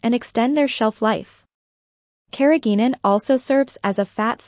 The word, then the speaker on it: carrageenan: Carrageenan, pronounced carrageenan, is an FDA-approved food additive, also known as E407, that is derived from red seaweed and consists of large sugar molecules.